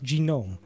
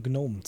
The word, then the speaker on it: Gnome: Also irgendwas Amerikanisches heißt Genome. Wie sollte man darauf kommen, dass die Leute das tatsächlich eher deutsch aussprechen und Gnome zu den Dingen sagen?